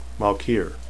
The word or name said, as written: malkier